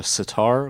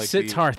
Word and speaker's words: sitar: Zitar, I don't know how to pronounce it. – Are you talking about a sitar? – A sitar. Thank you.